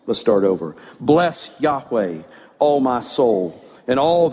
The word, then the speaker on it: Yahweh: But behind that in Hebrew are the letters Y-H-W-H, which is the covenant name of God. His name, as best we know how to pronounce it, is Yahweh.